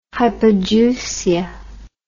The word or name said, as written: hypogeusia